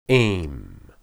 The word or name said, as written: em